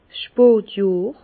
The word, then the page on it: 's Spotjohr: Bas Rhin; Herrlisheim